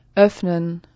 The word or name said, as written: oeffnen